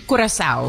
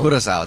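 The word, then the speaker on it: Curaçao: How do you say it again? What was the Curacao? – Curacao, that's beautiful. That's I'm going to try to say it that way.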